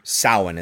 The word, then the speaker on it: Samhain: And then he goes in a long time talking about the ancient tradition of Samhain, or Samhain is how you actually pronounce it in the Celtic.